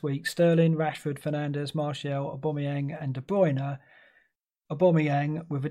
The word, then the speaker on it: Aubameyang: a lot of talk about Aubameyang. He's got to be. Did I pronounce that correctly? A lot of people saying I'm pronouncing Aubameyang wrong. – That was right. That was it. – Yeah, Aubameyang.